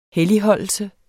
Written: helligholdelse

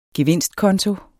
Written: gevinstkonto

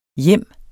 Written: hjem

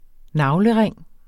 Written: navlering

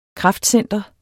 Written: kraftcenter

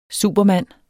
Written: supermand

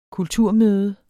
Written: kulturmøde

